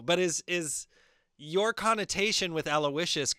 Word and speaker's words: Aloysius: Allo, and then the Y is the I. And then... – Aloysius. Aloysius.